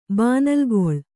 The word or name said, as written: ಬಾನಲ್ಗೊಳ್